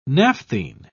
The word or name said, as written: naphthene